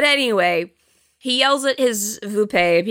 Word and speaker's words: Vuppe: I wrote the subtitle, which is V-U-P-P-E, and then I wrote how you pronounce it, which is V-O-O-P-A-Y, Vupe. That's what he says.